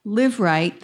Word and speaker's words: Liveright: I'd also like to thank LiveRight, the publisher. Am I saying that correctly? Yes, okay.